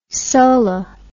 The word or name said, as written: soli